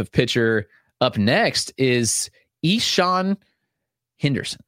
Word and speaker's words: Ixan: Now, if you look at it, it's not spelled this way at all. He's actually spelled I-X-A-N, but the correct pronunciation is Ishan, like Ishan. So it's I-X-A-N, Henderson, like normal Henderson, left-handed pitcher in the Cardinals organization.